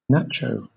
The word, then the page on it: nacho: British English